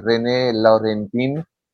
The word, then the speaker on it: René Laurentin: Y he trabajado con el famoso padre René Laurentin, en español se pronuncia René Laurentin, antes que lo hicieron un monseñor, ¿no?